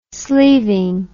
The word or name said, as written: sleeving